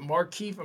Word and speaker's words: Markieff: And most people think they're trying to get Markieff. I'm thinking I'm saying this correctly. Markieff Morris. – Yeah.